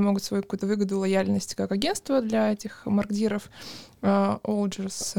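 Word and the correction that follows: Odgers: uh, Оджерс.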